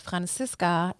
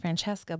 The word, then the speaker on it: Francisca: So it's pronounced Francisca instead of Francesca, but in English it's pronounced Francesca, but it's spelled Francisca.